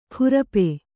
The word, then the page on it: ಖುರಪೆ: khurape